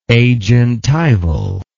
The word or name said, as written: agentival